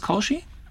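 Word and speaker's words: Caushi: Genau, und der Autor ist Bekim Kaushi, spricht sich das Kaushi? – Genau. – Also mit CAU geschrieben.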